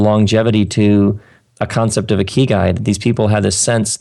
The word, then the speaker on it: ikigai: And what I want to bring up now is what you term, tell me if I'm saying this incorrectly, the ikigai. How do I say that? – Ikigai? It's that Japanese word, ikigai.